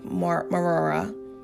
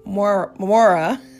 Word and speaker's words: Moriah: Then God said, take your son, your only son whom you love, Isaac, and go to the place of Moriah. I think I said it wrong. Moriah.